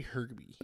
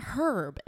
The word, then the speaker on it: herb: Buttercasse and some herb, which is very herby. – Okay, it's not herb. It's herb. There is no, the H is silent, Trent.